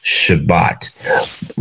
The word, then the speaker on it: Shabbat: In Hebrew, the word for Sabbath, or the Hebrew word for Sabbath, come from the word that is pronounced Shabbat, which means to rest.